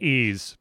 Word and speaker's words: Ys: Ease, by the way. – What? Oh, it's pronounced Ease.